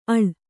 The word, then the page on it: ಅಳ್: Kannada